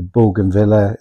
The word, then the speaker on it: bougainvillea: So I think you'd agree, it feels like the bougainvillea, is how you pronounce it, the flowers with the wooden balconies and the whitewashed walls.